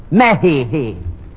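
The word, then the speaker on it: México: The correct Spanish pronunciation is Mexico. Mehihi.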